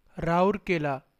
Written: Rourkela

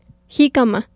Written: Jicama

